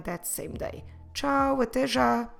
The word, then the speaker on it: já: The first word I want to tell you about is the word já. Já. Como se escreve já? J-A com acento. Já.